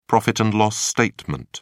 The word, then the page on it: profit-and-loss statement: British English